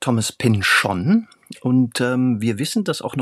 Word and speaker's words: Pynchon: Aber wir wissen nun immerhin, dass er Thomas Pynchon sagt. – Das heißt, sozusagen in dieser Serie hat man ihn so ausgesprochen. Ja, es hat sich aber anscheinend, würde ich sagen, 20 Jahre ist es jetzt her, nicht groß rumgesprochen.